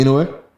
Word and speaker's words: Inoue: Inoue.